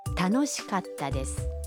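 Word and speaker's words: تانوشیکاتا دس (楽しかったです): در کلمه کاتا بین کا و تا یک مکس خیلی کوتاه داریم. یعنی در واقع صدای ت با تشدید تلفظ میشه. – تانوشیکاتا دس.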